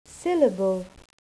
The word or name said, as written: syllable